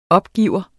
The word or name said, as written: opgiver